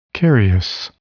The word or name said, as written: carious